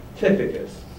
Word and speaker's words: Tychicus: And I had to look up the pronunciation to make sure I didn't get it wrong, because I'm very poor on reading some of these names. But this guy is Tychicus.